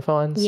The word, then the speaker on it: Fiennes: Is his last name Fiennes? – Yes.